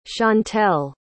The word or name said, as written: chantel